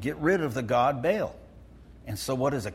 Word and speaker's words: Baal: Probably the correct pronunciation is Baal, but we anglicize it to Baal. Okay, so we might as well call it Baal.